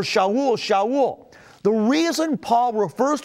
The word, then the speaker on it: Shaul: As a Roman citizen, Paul had both a Hebrew name that we call Saul, or it's pronounced in Hebrew, Shaul, and he had a Roman name, Paul.